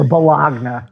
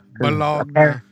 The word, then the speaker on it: bologna: Or bologna. – Bologna. – Apparently, if you call it bologna, you're saying it wrong. – Yeah, it's bologna.